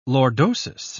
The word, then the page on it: lordosis: British English